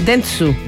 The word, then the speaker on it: Dentsu: Dentsu.